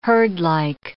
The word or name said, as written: herdlike